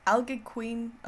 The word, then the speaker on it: Algonquin: Although descriptions can vary somewhat, common to all the Allegheny... Algonquin, no, I've said this differently each time, sorry. Algonquin.